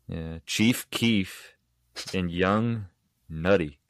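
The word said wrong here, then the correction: Nudy: Nudie. Young Nudie.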